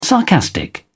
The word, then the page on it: sarcastic: British English